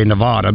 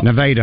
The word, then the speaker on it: Nevada: Or I believe we say Nevada, but Nevada, I believe, is how they say it.